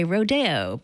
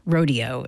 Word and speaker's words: Rodeo: It's from the ballet Rodeo by Aaron Copland, or Rodeo, it's sometimes pronounced, Buckaroo Holiday, Chorale Nocturne, Saturday Night Waltz, and Hoedown with the Cincinnati Pops Orchestra and conductor Eric Kunzel.